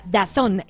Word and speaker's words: DAZN: DAZN, pero ya sabes que se pronuncia DAZN.